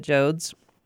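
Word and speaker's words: Joad: Well, Steinbeck took, what was going on in the natural world, but what he's really famous for is his characters, the Joads And is that how you say it, the Jodes? – Yep. The Jode family from Oklahoma.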